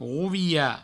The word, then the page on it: rovilhar: rouviha